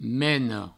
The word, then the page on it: mena: meno